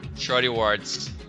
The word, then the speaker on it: Shorty Awards: Shorty Awards.